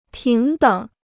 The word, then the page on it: 平等: píng děng